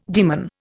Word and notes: demon: Indian

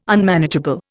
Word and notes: Unmanageable: Indian

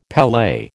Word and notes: pele: American English

